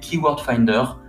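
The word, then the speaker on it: KWFinder: eh je te recommande d'utiliser Keyword Finder.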